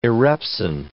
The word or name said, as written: erepsin